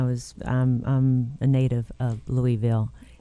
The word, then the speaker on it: Louisville: And so if you're named after King Louis, it's Louisville.